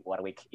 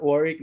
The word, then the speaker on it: Warwick: Persatuan Pelajar Indonesia di Warwick, Inggris. Anjo. – Bacanya Warwick, man.